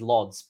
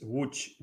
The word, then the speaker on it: Łódź: I pronounce it as Lodz, but I've been told very quickly in Polish it is not Lodz. What is it? – Hi, everybody. It's Łódź, GT Łódź. – I read L-O-D-Z, but there's also a lot of squiggly nines.